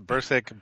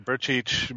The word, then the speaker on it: Brcic: There's many variations. Brcic, Brcic, Brcaci, whatever. – Brcaci. Brcic sounds more the way it's supposed to sound like.